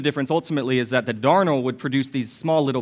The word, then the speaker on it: darnel: And if you look over on the notes, it says darnel. As far as I know, that's how you pronounce it, darnel.